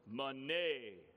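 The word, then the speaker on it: Mene: The first syllable has like a short E sound, and then the second syllable has like a long A sound. And so it's Mane, Tekel, Peres.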